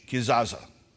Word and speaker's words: kezazah: We would spell it in the English K-E-Z-A-Z-A-H, Kizaza.